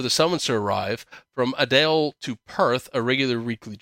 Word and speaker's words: Adelaide: Adelaide.